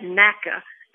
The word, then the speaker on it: Natchitoches: But you got to get that NACA at the beginning. And the emphasis on that is on that first syllable on the N-A.